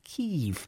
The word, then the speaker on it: Kyiv: the least we can do is pronounce their capital city the way they want it pronounced, because there's nothing annoys us more as Irish people if people get the pronunciation of our names or towns, lands wrong. So it, from here on in, it will be forever known as Kyiv.